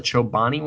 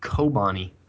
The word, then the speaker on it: Chobani: Already, I've always pronounced it Kobani, and so I'm already learned.